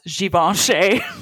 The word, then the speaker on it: Givenchy: And also that Givenchy is pronounced Givenchy.